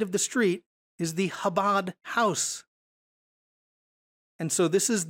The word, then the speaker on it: Chabad: And you would get something like this, and you might pronounce it Chabad.